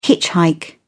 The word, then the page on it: hitchhike: British English